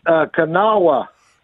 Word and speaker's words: Kanawha: It was Kanawha.